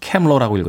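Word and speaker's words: Camp Lo: 캄로의 음악 준비했습니다. 캠로라고 읽어야죠. 캠로.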